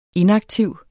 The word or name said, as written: inaktiv